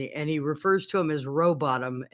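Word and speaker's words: Rowbotham: I was just going to tell you that it's pronounced Samuel Robottom. And I was looking to find the funny story because somebody claimed to have known him. Go ahead. – Oh, I was going to say, is the H silent? – He said, yeah, it's pronounced Robottom.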